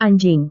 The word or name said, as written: anjing